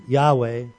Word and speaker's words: Yahweh: So you will find when you read and study about this that Yahweh, YAHWEH, is the accepted pronunciation and spelling of God's name.